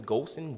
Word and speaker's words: Golson: So if you just spell it right, last name is Golson, G-O-L-S-O-N.